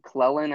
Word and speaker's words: Clelin: Yeah, I was just reading about, I think it's Clelin, I believe is how you pronounce the first name, Clelin Farrell out of Clemson a few years back.